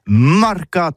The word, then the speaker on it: marcat: Eh bien, disais à tous, mais le mercat de l'Uni ou le mercat des rebelles, mais en gascogne toulousano, c'est prononcé les marcat avec un A marquette. – En gascogne toulousaine, bah, du côté par exemple de l'île des Bâches, c'est l'île Jourdain, pour parler du marché, vous prononcez les marcat.